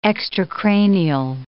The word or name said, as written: extracranial